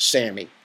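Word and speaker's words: SAMe: But it's spelled S-A-M, those are all in caps, dash, small e, and it's pronounced SAMI.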